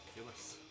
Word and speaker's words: Gillies: Ben Gillies or Gillis? – Gillis.